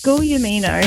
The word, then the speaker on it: Giuliamino: It's Giuliamino. – Giuliamino. Great name. – Lots of silent letters.